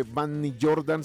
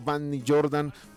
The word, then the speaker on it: Vanny Jordan: Bueno, marcando mi nombre, Vanny Jordan. – ¿Cómo se escribe? – Se escribe B pequeña, o sea, V, B de vaca, A-N-N-Y, y después espacio Jordan, J-O-R-D-A-N, Vanny Jordan.